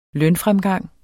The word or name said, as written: lønfremgang